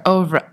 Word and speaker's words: oeuvre: like I can never say this word, oeuvre. How do you say it? – Oeuvre, I think.